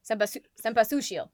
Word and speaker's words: Cempasúchil: Cempasúchil. No, Cempasúchil. There we go. The accent is on the U.